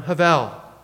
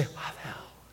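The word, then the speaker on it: havel (הבל): It's a Hebrew term, havel. You say, havel. It sounds just like that. That's how you pronounce it.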